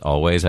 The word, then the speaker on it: Alvvays: If you want to check them out, by the way, their name is spelled Alvvays, but they're pronounced Always, I believe.